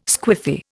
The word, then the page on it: squiffy: Indian